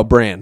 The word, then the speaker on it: Bran: Bram? Am I saying that right? The little boy that can't walk? – Oh, Bran.